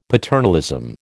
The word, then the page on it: paternalism: American English